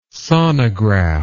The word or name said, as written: sonograph